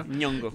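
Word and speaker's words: Nyong'o: Que van a premiar a, a Bagdad Abdi, ya se ganó el, el BAFTA que premiarían a Lupita Moñoñongo, ¿cómo se dice? – Oh, bueno, Ñongo. – Ñongo, eso.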